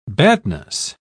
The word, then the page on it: badness: British English